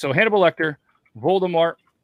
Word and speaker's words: Voldemort: Voldemort? – Yeah.